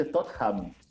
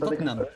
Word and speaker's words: Tottenham: Y también, no, sé después lo vamos a revisar, pero el Tottenham se dice Tottenham. Y la gente le dice Tottenham.